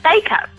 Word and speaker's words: Bacup: Backup coconutters. – Yeah, bake-up. – Bake-up, is that how you pronounce it? – Yeah. – It's, okay, listen, everyone, if you're at home, go and type this into Google. Bake-up, B-A-C-U-P, coconutters.